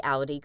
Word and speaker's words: Audi: The Audi car. – The Audi? – A-U-D-I.